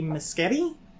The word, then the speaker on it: Muschietti: Directed by Andy Muschietti. He is an Italian filmmaker whose last name rhymes with spaghetti.